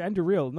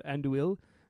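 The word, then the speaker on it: Andúril: Like, wait a second, it's Anduril, not Anduil.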